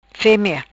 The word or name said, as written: femea